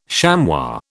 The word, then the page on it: chamois: British English